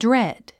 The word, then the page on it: dread: dréd; ドレド